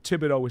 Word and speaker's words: Thibodeau: And I thought it was really cool because Thibodeau said, and I got to make sure I don't pronounce the H. Thibodeau is how he says it, not Thibodeau.